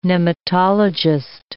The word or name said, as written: nematologist